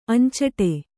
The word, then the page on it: ಅಂಚಡಿ: añcaḍi